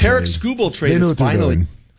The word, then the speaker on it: Tarik Skubal: How to say his name is, it's Tarik Skubal.